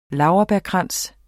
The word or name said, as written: laurbærkrans